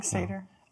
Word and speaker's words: satyr: Is it satyr? – Yeah, a satyr. – I see that Y and I'm like, man, I could pronounce that all kinds of ways. – In the lyrics to Disney's Hercules, he says a satyr, so that's how I know.